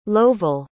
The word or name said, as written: lovel